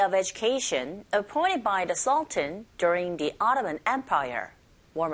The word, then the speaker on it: sultan: 네, 우리는 술탄이라고 읽지만 발음은 sultan 이렇게 하시면 돼요.